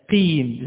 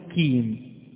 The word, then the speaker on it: المستقيم: نلاحظ أن البعض يضغط جيدا على الصاد ثم الطاء فيخرجها جميلة واضحة، ثم يقصر في النطق بالسين، يقول المستقيم المستقيم. اسمها صاد أم سين؟ سين. إذا نقول المستاء المستاء، ألف لام ميم سين تاء، سين تاء المستاء قيم، لا نقول كيم. كيم هذه كاف مكسورة.